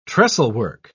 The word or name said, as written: trestlework